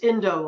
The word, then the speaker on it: Endo: We all pronounced his name wrong for years, and then he told us years later, it's Endo like window.